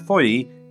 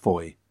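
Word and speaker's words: Fowey: He also reminded me that the port in Cornwall, which I pronounced Foy in the Britain and the Sea episodes for members, is properly pronounced Foy.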